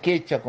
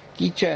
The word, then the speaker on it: quechua: O los comichingones, que también habitaban Córdoba y parte de Santiago, Catamarca, pero el quechua concretamente, o quichua como dice ahí, quichua en realidad es una pronunciación bastarda, digamos, podemos decirlo así, de esta cuestión de la conquista.